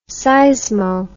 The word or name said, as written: seismo